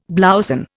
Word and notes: blouson: Indian

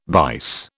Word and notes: bice: British English

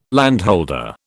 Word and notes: landholder: British English

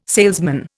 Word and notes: salesman: Indian

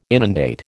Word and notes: inundate: American English

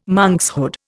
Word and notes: monkshood: Indian

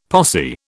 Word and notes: posse: British English

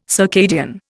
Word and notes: circadian: Indian

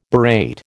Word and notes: berate: American English

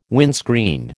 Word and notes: windscreen: American English

